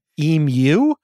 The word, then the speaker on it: emu: but I think it's emu or emu, emu, is that right? – Emu is correct.